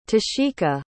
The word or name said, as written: tasheika